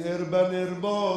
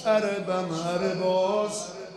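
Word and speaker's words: اربا اربا: گفت این اربن اربا غلط مشهوره، تلفظ صحیحش اربن ارباز،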